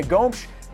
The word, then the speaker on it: Gomes: Thank you for saying Gomes. That's how she would pronounce it. – You're welcome. – In Portuguese. In Portugal. Let's go.